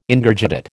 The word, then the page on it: Ingurgitate: American English